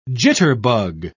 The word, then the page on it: jitterbug: British English